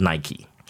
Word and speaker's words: Nike: And then only much later did I learn in college that Nike was named the goddess of victory. So Nike, the goddess of victory. So you pronounce it Nike. That's why.